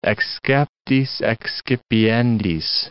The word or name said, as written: exceptis excipiendis